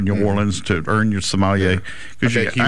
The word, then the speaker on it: sommelier: Yeah, a sommelier.